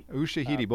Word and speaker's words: Ushahidi: Am I pronouncing that right? Ashida? – Ushahidi.